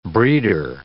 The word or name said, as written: breeder